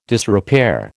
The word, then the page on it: disrepair: American English